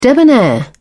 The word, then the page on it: debonair: British English